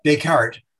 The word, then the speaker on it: Descartes: How do you pronounce the card in English? – Descartes. – Descartes said, thank you.